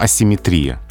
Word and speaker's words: асимметрия: что по-русски правильно симметрия, но асимметрия.